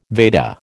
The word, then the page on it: Veda: American English